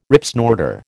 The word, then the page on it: Ripsnorter: American English